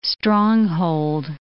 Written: stronghold

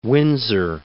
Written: Winsor

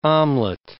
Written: omelette

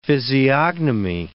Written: physiognomy

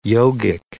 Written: yogic

